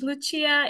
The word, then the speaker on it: Lucia: Thank you so much, Lucia. If I can pronounce it the Italian way, whatever you prefer.